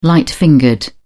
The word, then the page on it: light-fingered: British English